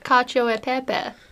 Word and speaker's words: cacio e pepe: It's like cacio e pepe. That's how you say it.